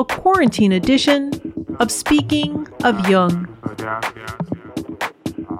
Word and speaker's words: Jung: You can also listen to this episode on your Amazon Echo device, simply by saying Alexa, play Speaking of Jung on Apple Podcasts. Just be sure to pronounce Jung with a hard J.